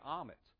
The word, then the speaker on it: Ahmet: and there you get Ahmet. Ahmet's actually a Turkish name, but there's no Muslim heritage in my life. I'm half Cuban and half Italian. But growing up, rhyming with words like comet and vomit, I wasn't the cool kid, all right?